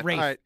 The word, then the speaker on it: Ralph: Does Rafe Fiennes? – It's spelled, but I don't know. – Well, it's spelled R-A-L-P-A. – Spelled Ralph. It is Rafe, but.